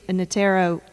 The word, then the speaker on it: Notaro: Tig Notaro, am I saying that right? – Mm-hmm.